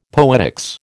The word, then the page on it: poetics: American English